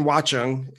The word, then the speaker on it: Watchung: So according to my notes, you grew up in New Jersey in Watchung. Is that pronounced right? I'm always you got it.